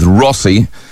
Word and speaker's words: Rossie: Start your text with Rossi, R-O-S-S-I-E is the way I spell it, R-O-S-S-I-E.